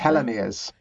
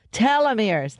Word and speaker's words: telomeres: Now, this plays into what we call trimmed telomerase. Did I say that correctly? – Telomeres. – Telomeres.